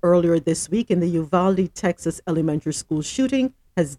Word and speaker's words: Uvalde: If you hear me go from saying Uvalde to Uvalde, please forgive me, because the Uvalde is this pronunciation, of course, is the Latin pronunciation for that town. And of course, we know Texas was a part of Mexico at one point, but the American pronunciation is Uvalde.